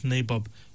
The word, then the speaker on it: Nabob: It's pronounced as Nabob. It is spelled N-A-B-O-B, Nabob.